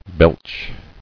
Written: Belching

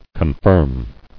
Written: Confirmer

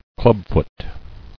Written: Clubfooted

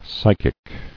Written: Psychic

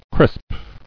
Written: Crisping

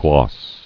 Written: Glossing

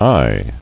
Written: Aye

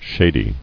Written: Shady

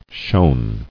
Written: Shone

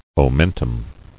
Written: Omental

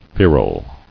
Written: Feral

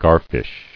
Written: Garfish

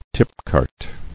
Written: Tipcart

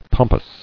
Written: Pomposity

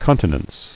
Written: Continence

